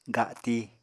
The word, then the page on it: gati: Apatani